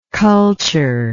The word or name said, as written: cultureless